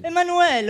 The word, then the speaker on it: Emmanuel: Dunque, brani cantati da Mina e Giulia De Palma, ma è Emmanuel. – È francese. – Emmanuel, ho sbagliato la pronuncia, oh quante arie, mamma mia.